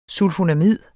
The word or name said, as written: sulfonamid